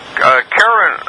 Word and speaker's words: Charon: I pronounce it Karen, and I don't remember who told me that, because my first teacher pronounced it Sharon.